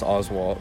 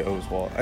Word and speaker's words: Oswalt: My last name's pronounced Oswalt, but a lot of people say Oswalt. I mean, either way is fine.